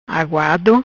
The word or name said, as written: Aguado